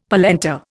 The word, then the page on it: polenta: Indian